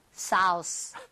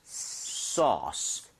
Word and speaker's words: sauce: AU나 AW 스펠링이 나오면 O보다 조금만 입모양을 벌려준다. 이 느낌. 그래서 소스보다는요. 소스. Sauce. 살짝만 벌리시면 되거든요.